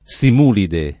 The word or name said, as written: simulide